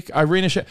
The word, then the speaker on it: Irina Shayk: How do you pronounce her last name? – Irina Shayk.